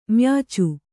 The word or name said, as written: ಮ್ಯಾಚು